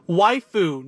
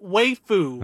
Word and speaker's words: waifu: And remember, Sean, it's pronounced waifu, not waifu. Waifu? Like wife, but foo. – Oh, okay. It's spelled W-A-I-F-U.